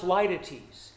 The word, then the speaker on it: litotes: L-I-T-O-T-E-S, pronounced Lytetes.